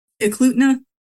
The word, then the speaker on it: Eklutna: We're talking about a cigar-shaped object in 1936 out of, is it Eklutna? Did I say that right? – Eklutna, yeah.